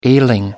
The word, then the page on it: ailing: American English